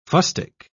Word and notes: fustic: British English

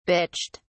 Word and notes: bitched: British English

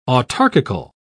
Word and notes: autarkical: British English

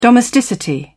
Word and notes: domesticity: British English

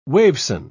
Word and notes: waveson: British English

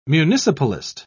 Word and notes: municipalist: British English

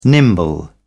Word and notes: nimble: British English

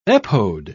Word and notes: epode: British English

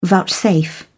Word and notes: vouchsafe: British English

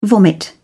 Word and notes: vomit: British English

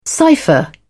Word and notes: cypher: British English

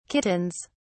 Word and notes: kittens: British English

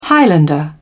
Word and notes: highlander: British English

